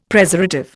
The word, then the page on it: Preservative: Indian